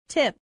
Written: tip

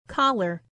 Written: collar

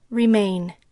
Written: remain

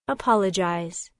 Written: apologize

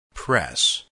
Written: press

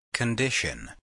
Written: condition

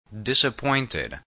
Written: disappointed

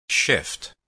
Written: shift

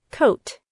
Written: coat

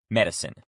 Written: medicine

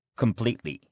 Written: completely

